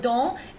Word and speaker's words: dans: So the first word dans is spelled D-A-N-S. So A-N becomes an A nasal. Sometimes also the E-N in the second line, which is ardent. – Ardent. A-R-D-E-N-T.